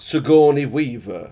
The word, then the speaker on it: Sigourney Weaver: Dolph Lundgren and Sigourney Weaver. – You can't say it like that. – Sigourney Weaver.